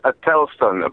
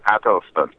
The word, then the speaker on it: Adelston: Well, it's Adelston, but Americans have a different sort of cadence. They say Adelston, but it is Adelston Fitzgerald Holder the First.